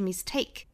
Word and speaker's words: mistake: mistake 稍微有一点点 t 的发音，但是基本上是听不到送气声音的，不是 mistake 应该是 mistake。